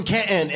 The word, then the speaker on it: Canton: You're from Canton, and you say it right. I'm from Canton.